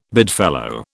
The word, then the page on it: bedfellow: British English